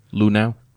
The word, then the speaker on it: Luhnow: Luhnow.